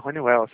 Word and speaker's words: Honeywell: Ah, Honeywell, sim, isso mesmo.